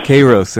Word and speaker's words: Kairos: I'm talking about Kairos, is that pronounced correctly? And Kronos? – Yeah, Kairos and Kronos.